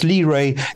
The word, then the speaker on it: Li Rui: Yes, Li Rui is a fantastic person. R-U-I, I didn't know how to pronounce it. – Yes, exactly. L-I is a surname, and R-U-I, Li Rui.